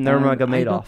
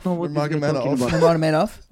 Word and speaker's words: Nurmagomedov: Habib, Habib um, Nurmagomedov. Nurmagomedov. I told her the wrong pronunciation. She's gonna Wait, fuck up on TV. Nurmagomedov.